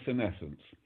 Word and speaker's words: senescence: So it's actually normally pronounced senescence.